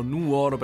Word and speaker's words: Nuoro: Comunque siamo in provincia di Nuoro e mi raccomando Nuoro perché se mettete l'accento sulla O siete delle bestie e dovete morire dissanguati.